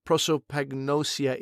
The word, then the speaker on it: prosopagnosia: So let's go and just talk about what the definition of prosopagnosia is. And am I even pronouncing it properly? – Yeah, it's pretty good pronunciation. Pretty, pretty good.